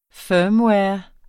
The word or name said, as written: firmware